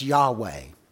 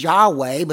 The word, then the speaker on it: Yahweh: Now, scholars have decided that it is Yahweh, but you've got to remember that that's not necessarily exactly right.